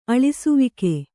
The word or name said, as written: ಅಳಿಸುವಿಕೆ